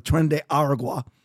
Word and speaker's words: Tren de Aragua: Due to the Biden-Harris administration allowing millions of, and everyone needs to remember, Tren de Agua, if I'm saying that right, Tren de Agua, I'm just going to pronounce it that way. It's Aragua, sort of, Tren de Aragua.